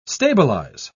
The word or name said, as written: stabilize